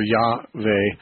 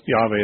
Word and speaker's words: Yahweh: And they took the vowels from the name Adonai and put them with the tetragram and came up with Jehovah or Yahweh. It can be pronounced either way, but Yahweh is somewhat more Hebrew sounding.